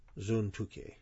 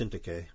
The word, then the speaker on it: Syntyche: Now, I'll stop and say, you say, well, the English says S-Y-N and T-Y. Well, in the Greek, it's actually a upsilon. It's a U sound, U. Syntyche. And so it's usually pronounced Syntyche, but that's because of the way it's being transliterated in the English.